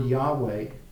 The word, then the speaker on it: Yahweh: And we also see the Hebrew word Yahweh. Now that's how we pronounce it. That it's actually, in the original Hebrew, it's just four letters. And again, you map that to letters that we recognize. It's four consonants, Y-H-W-H.